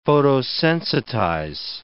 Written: photosensitize

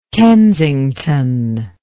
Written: Kensington

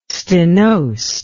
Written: stenosed

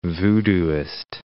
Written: voodooist

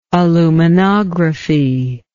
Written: aluminography

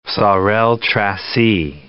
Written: Sorel-Tracy

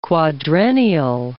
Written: quadrennial

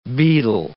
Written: beadle

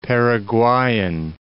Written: Paraguayan

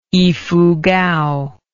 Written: ifugao

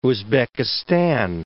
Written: Uzbekistan

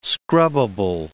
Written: scrubbable

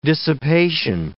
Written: dissipation

